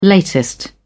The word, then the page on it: latest: British English